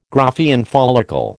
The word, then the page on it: Graafian follicle: American English